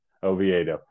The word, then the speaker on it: Oviedo: Oviedo.